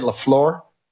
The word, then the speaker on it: LaFleur: And their coach, LaFleur? Is that how you say it? LaFleur? – Mm-hmm.